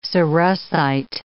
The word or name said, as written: cerussite